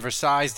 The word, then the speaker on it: Versailles: I'm stunned that they had 600 people from Louisiana at Versailles, and Versailles didn't end up getting burned to the ground.